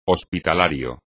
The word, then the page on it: hospitalario: espagnol